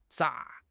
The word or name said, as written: 咋